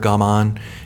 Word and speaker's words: gaman: So, and it immediately makes, or gaman is, people say it in English pronunciation, but I think it's gaman is more of a Japanese pronunciation. And so there's a book, The Art of Gaman, mm-hmm.